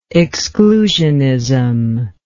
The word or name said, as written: exclusionism